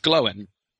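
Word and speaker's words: Glóin: And similarly, G-L-O-I-N is not Gloin. It's Glowin.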